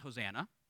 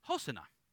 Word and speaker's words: Hosanna: And it's not pronounced Hosanna. it's pronounced Hosanna.